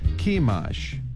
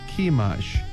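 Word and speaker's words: Chemosh: Sihon, S-I-H-O-N, Sihon 이렇게 발음해 주시고 그모스는 C-H-E-M-O-S-H입니다. 그래서 키마쉬, 한 번 더요. 키마쉬, 그모스를 키마쉬 이렇게 연습해 주시기 바랍니다.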